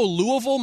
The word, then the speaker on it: Louisville: I go Louisville myself because that's what the locals say.